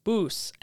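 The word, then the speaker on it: bouse: When looking at this word, it seemed really strange to me that it was pronounced like boos and not like house or mounce would be pronounced.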